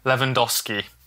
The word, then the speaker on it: Lewandowski: So it's Samantha Kavang Lewandowski.